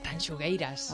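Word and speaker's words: Tanxugueiras: mira que me cuesta decir el nombre. Venga. Tanchugueiras. – Las Tanchugueiras. – Ahora lo he dicho bien.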